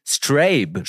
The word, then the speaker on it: Strabe: Strabe spricht man das Projekt aus. Mhm. S-T-R-A-B-E geschrieben aus Dublin, mittlerweile glaube ich sogar aus London.